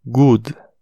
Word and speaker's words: good: Exemplo: good, G-O-O-D, good significa bom. É, apesar de ter som de U, a gente tem dois Os ali, G-O-O-D.